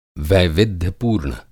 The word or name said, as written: वैविध्य-पूर्ण